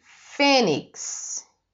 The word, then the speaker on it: Fênix: FE, né, com esse acento circunflexo, são, é a sílaba mais forte. Fênix. Fênix é, é uma palavra também paroxítona, porque ela tem N e X no final, mas não é o mais forte. O mais forte é o FE.